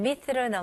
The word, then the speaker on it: myth: 여기서는 이제 TH 발음이 나왔습니다. Okay, 여기서는 myth. 에 발음, I 발음으로 생각하시고요. 마지막 TH 발음, 혹시 못하시면 이거는 살짝 메롱으로 생각하시면 돼요.